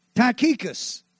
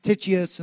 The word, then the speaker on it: Tychicus: Tychicus That's the way I've heard it pronounced. You've heard it pronounced Tychius and all kinds of stuff, but you can go, but Tychicus I like, because that's Texan.